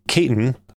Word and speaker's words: Caton: where Caton, hope that's how you pronounce it, it's C-A-T-O-N, Caton will revive the iconic sports car.